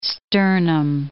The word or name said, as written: sternum